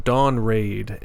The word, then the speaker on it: Dawn Ray'd: I can't even remember how I stumbled upon them, but I thought their debut was really good, and the band is called Dawn Raid, and it's Dawn, you know, the normal way of spelling it, but Raid is spelled R-A-Y apostrophe D.